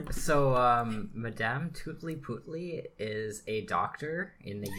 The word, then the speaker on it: Tutli-Putli: It's called Madame Tutli-Putli. – Oh. – It's pronounced how it's spelt. Spelt okay. – How it's pronounced. – Tutli-Putli.